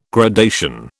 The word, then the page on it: gradation: British English